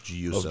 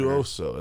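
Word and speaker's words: Juuso: it's J-U-U-S-O. – I know, but when he was pronouncing it, there was like a not like Crafin a or something. Juoso. Or? It's like there was it was a pause between the two U's.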